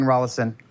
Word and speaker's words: Rollison: Rolling on to Rollison. No, that's not how you pronounce it. It's Ryan Rollison.